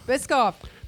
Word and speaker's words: Biscoff: Bischoff cookie butter. – Is that how you say it? – Is it Bischoff? – Biscoff.